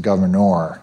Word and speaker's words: Gouverneur: They pronounce it Gouverneur, where his summer house is still standing.